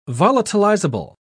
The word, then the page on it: volatilizable: British English